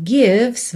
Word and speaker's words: gives: Então, gives, você não pronuncia a letra E, do V vai pro S.